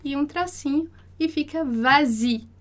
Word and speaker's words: vas-y: A gente tem va-i, quer dizer, vá lá. Então, para facilitar a pronúncia, a gente vai colocar o S, para conseguir fazer a liaison. Então, a gente vai dizer vas-y, tá? Porque va-i, dá um problema ali, de encontro, né, no som, de encontro de vogais, va-i.